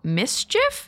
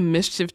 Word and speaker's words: MSCHF: a design studio called Mischief. It's spelled M-S-C-H-F, so I'm going to go ahead and make an assumption that's how you pronounce it.